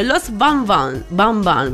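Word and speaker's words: Los Van Van: Posizione numero 5, Los Bambam, perché loro la V la pronunciano B, questa è una osservazione da fare.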